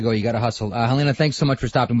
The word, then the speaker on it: Helene: It is Helena St. James, H-E-L-E-N-E, although pronounced Helena, S-T James on Twitter.